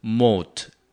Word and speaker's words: moat: 그 ditch라는 말을 딴 말로 쓰면은 해자 도랑이라고 그러지 않고 보통은 해자라고 얘기하는데 이 해자는 영어로 moat. M-O-A-T가 됩니다. 여러분, 저 성 같은 거 있으면 성 가장자리에 적군이 오지 못하기 위해서 연못을 파놓죠. 그런 연못을 해자라고 얘기합니다. 그리고 그걸 영어로는 moat, 연못의 못, 발음은 비슷해요. M-O-A-T, 그래서 ditch라는 거에 더 유식한 말은 moat, M-O-A-T, 해자가 되겠습니다.